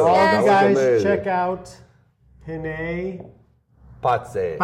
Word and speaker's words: Penne Pazze: It's not penne. It's penne pazze. – Penne. See, I try to pronounce, but I take too long on the pronunciation. – No. It's like it's penne. – Penne. – Penne. – Pazze. – Pazze. – Penne pazze. It's penne, one penne, word, pazze. Two capital P's.